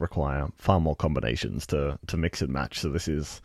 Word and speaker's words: Mx: So I have to put up my hand and say I had to ask a friend, but I believe it's pronounced mix.